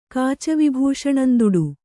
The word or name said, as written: ಕಾಚವಿಭೂಷಣಂದುಡು